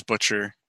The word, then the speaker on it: Butcher: I always say it wrong. I say it butcher, but it's not pronounced butcher. Is there? Is it Jim, the author of Dresden Files? – I've always heard it pronounced as butcher.